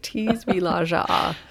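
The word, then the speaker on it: Tisvildeleje: Tisvilaja, which sounds remarkably close to P wants to play in Danish.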